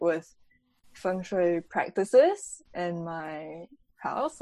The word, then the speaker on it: feng shui: I guess somewhat related to kind of like putting stuff around your room and house is like feng shui. I don't, how do you say that in English? – Feng shui.